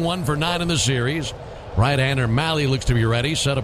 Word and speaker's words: Mahle: Tyler Malley, last name spelled M-A-H-L-E, and pronounced Malley, rhymes with rally. Heard a few different pronunciations, and Malley is the one that he goes with.